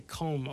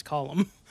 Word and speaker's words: Colm: so so column because that it it's column in, in Ireland, and we say comb over here, right? – Yeah, like Colm Meaney. – Yeah, but I believe it's pronounced column.